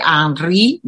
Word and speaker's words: Henri: Se escribe Henry, pero como es francés, se dice Henri Berson y tiene un libro sobre la risa.